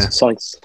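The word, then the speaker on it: Saïss: Yeah, Sais.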